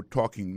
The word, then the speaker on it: talking: I'll be talking about, moi pral parler sous, I will be talking about, regardez bien pour le mot talking, non? Qui c'est participe présent, conjugué au futur, c'est I'll be talking about, ou accent tonique là, qu'on doit prononcer à tomber sous premier syllabe.